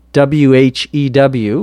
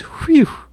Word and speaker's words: whew: W H E W, which we would pronounce, I guess, as whew or whew.